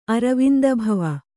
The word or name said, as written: ಅರವಿಂದಭವ